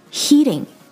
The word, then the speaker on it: heating: 者是警察 police 的时候我们说的一定是 someone is hitting me 而不是 hitting on me 这一点千万要注意还有我们今天提到的其他的几个单词 heading towards heading H-E-A-D-I-N-G heading 朝某个方向而去和 heating H-E-A-T-I-N-G heating 暖气 heading, heating 和 hitting, H-I-T-T-I-N-G, heading, heating, hitting. 这三个单词要把它区分开来的话元音发音是至关重要的也是唯一一个从听力上能够把这三个单词区分开来的一个办法。所以标准的元音发音相当重要。